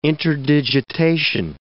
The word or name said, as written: interdigitation